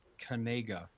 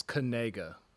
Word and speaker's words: Kanigga: The name is spelled Kanigga. And it's his real last name, apparently. Where does he play football? – Apparently, he made a TikTok saying that it's pronounced Kanega. – Yeah, it's Kanega.